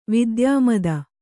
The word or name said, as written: ವಿದ್ಯಾಮದ